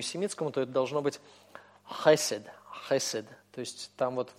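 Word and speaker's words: хесед (חסד): Да, вот скажем слово «милость», верная преданность, это начинается с буквы хет и произносится, соответственно, хесед, хесед, хесед, да. Вы можете сказать, в принципе, хесед, то есть просто как русское хэ, хесед. Это допустимо, но это не вполне точно.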